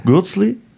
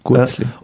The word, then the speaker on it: Gürzli: Und ich habe extra die berühmten, die heißen ja bei euch in der Schweiz ein bisschen anders, Weihnachtsgürzli, ist das richtig ausgesprochen? – Gürzli. – Gürzli.